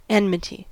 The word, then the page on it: enmity: American English